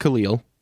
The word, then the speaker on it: Khalil: Of course, the name on everybody's lips this week, Khalil Ware, and it is pronounced Khalil for everyone out there.